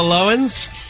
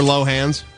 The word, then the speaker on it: Lohans: Speaking of the Loewens, or Lohans, depending on your pronunciation, Lindsay and Samantha Ronson, her lesbian girlfriend, now have matching tattoos.